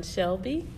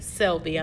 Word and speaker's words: Selby: Now, and I would like for you to meet Ms. Dion Shelby. Am I pronouncing your last name correctly? – It's close. Dion Selby. – Selby.